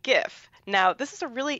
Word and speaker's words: GIF: because the inventor of the word GIF pronounces it GIF and has been very clear, like the peanut butter.